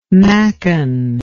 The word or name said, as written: machen